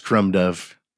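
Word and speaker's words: Crom Dubh: I mean, it's cool. It's C-R-O-M-D-U-B-H. – Okay. – Like Crumb Dove. – Yeah. – But it's pronounced Crumb Dove. – And it's the other name. One was Dire, the other Dire, yeah. D-A-I-R-E.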